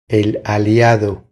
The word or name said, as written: El aliado